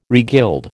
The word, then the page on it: regild: American English